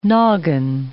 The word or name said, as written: nogging